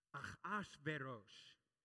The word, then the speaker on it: Ahasuerus: The Hebrew name is a little bit more difficult to pronounce. Ahasuerus.